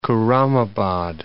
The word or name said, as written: Khurramabad